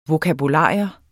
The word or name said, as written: vokabular